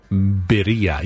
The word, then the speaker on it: birria: Berea? – Birria. You know I Birria. Can't roll my R's, but Birria. It's a double R, so. – Birria. I thought it was Birria. – Birria. Birria.